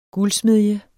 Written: guldsmedje

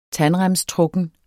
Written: tandremstrukken

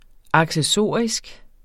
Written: accessorisk

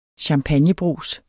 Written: champagnebrus

